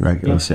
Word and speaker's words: Regulus: I think Creature was interesting to Regulus. Is that how you say it? Regulus? – Regulus, yeah.